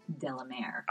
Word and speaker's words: de la Mare: Delamere.